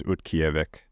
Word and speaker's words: Utqiagvik: Utqiagvik and uh,